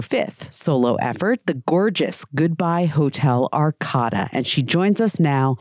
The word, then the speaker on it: Goodbye Hotel Arkada: Congratulations on the new album, Goodbye Hotel Arkada, you just told me is how it's pronounced.